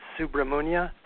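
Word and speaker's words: Subramuniya: So his name is Swami Subraniya. Am I saying that correctly? – It's Subramunya.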